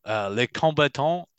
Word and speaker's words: Les combattants: Les combattants, the combatant. How am I doing on the pronunciation? First of all, no, you've done well there.